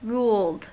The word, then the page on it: ruled: American English